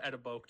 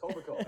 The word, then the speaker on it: Etobicoke: I used to call it Etobicoke, to be honest with you, because I just didn't know how to pronounce it. – It's Orbical.